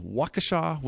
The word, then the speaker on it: Waukesha: So you're born in Waukesha, Wisconsin. – Yeah, it's a mouthful, isn't it? Did I say it right? Yeah, you did.